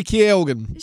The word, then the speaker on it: Keoghan: All right, Barry Cohegan. Did I say that right? I don't think so. – I don't think you did, but now I'm blanking on how to actually say it. – Keoghan. Barry Keoghan.